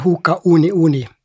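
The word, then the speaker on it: Hoka One One: Og vi skal jo egentlig uttale det Hoka Oni Oni. Det er jo vel bare i USA at de gjør det.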